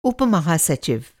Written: उप

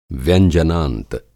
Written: व्यंजनांत

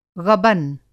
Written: ग़बन

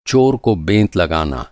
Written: बेंत

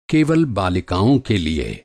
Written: बालिका

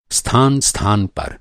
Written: स्थान

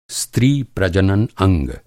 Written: प्रजनन

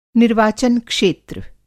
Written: निर्वाचन-क्षेत्र

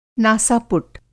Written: नासा-पुट

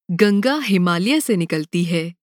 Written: हिमालय

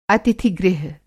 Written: अतिथि-गृह

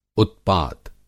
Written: उत्पात

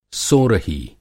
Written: सोरही

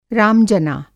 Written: राम-जना

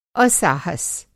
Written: असाहस

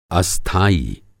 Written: अस्थाई

